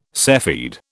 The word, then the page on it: cepheid: British English